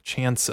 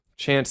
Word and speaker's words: Chants: And it's called uh, Chants of Sennaar. I think I'm saying that right. What a title! It's um, Chants C H A N T, like ch- people chanting.